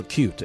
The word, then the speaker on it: acute: To also add to you, Mary, it's acute, not acute. It's acute. It's just the stress. – That's right. – It's acute. It's on the second syllable, and it's also, and this is a pronunciation thing.